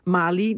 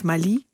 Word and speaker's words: Mali: En we gebruiken dus ook de Nederlandse uitspraak bij andere voormalige kolonies van Frankrijk, zoals Mali, niet Mali.